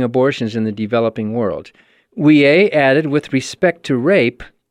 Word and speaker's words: Ouellet: Last weekend, Cardinal, how do you say that? Ouellet, O-U-E-L-L-E-T? – Yeah, Ouellet.